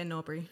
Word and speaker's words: Norbury: Yeah, Norbury.